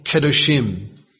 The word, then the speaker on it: Kedoshim: Here the reference is Elohim Kedoshim. Kedoshim. Leviticus 19 and verse 2. Kedoshim is spelled K-E-D-O-S-H-I-M.